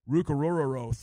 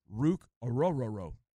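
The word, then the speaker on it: Ruke Orhorhoro: Ruka Rororo, 294. That's pronounced correctly. – Wait. – Braden Fiske, 292. What? – Pronounce that again. – Ruka Rororo.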